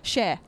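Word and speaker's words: Cher: Well, some people pronounce it share, some pronounce it sure. What would you say in conversation you're talking about that person? – Just like in general, share.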